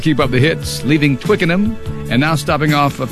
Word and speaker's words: Twickenham: Pronounce it now. – It's Twickenham. – Twickenham? – Yeah. – Did I get it right? – Yeah.